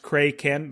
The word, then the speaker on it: Kraken: So apparently it's, at least I'm reading here on IMDb, they're saying it's supposed to be pronounced Kraken, like K-R-A-Y.